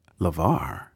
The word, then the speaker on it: LeVar: And I thought, LeVar rhymes with star.